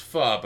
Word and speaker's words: pho: I went to a faux restaurant. – Oh hell yeah! I believe it's pronounced pho, but I'm being pedantic.